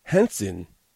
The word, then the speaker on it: Renson: Now, Henson, she specifies it's pronounced Henson. It's spelled R-E-N-S-O-N. To me, that's Renson. How do you get Henson from Renson?